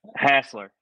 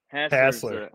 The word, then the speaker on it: Hasler: Hasler. Hasler. The correct pronunciation.